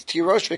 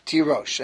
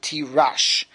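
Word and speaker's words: tirosh (תירוש): Now, it's not really a stira in the Pesukim, but the pronunciation of the words can come in one of two ways. Tirosh v'karinan, tirosh. In other words, the pronunciation, the correct pronunciation of the word is tirosh. But meanwhile, it's written without the vav, which means it could be read tirash.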